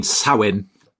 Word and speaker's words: Samhain: You, just before we started, I asked you how do you pronounce it because it's actually in English it looks like Sam Hain, which kind of sounds like a football manager or something, kind of big Sam.